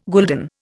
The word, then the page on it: gulden: Indian